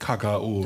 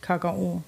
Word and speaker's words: Kakao: Ja, der Badener sagt Kakao. – Der sagt Kakao. Mit der Betonung, ganz wichtig. Kakao.